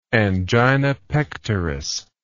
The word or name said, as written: angina pectoris